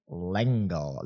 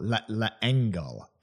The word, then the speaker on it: L'Engle: It was written in 1962, this version, by a lovely, talented woman named Madeleine Engle. L'Engle.